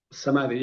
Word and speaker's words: Samadhi: Samadhi, yeah.